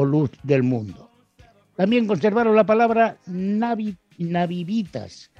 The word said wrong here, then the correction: nativitas: nativitas, perdón, o nativitas sería la pronunciación correcta, que en latín designaba el aniversario del nacimiento de un emperador o del día de su ascensión al trono.